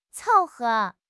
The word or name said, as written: 凑合